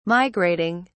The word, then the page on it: migrating: British English